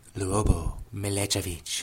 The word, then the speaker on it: Milicevic: Sorry, you didn't say it right. Try it again. – I didn't do it right. Lubo Milicevic.